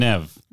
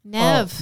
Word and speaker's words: Neve: So it's Lev and Neve? Or is it Lev and Nev? – Nev. – Oh, Nev as in Bev, right? – Nev as in Bev.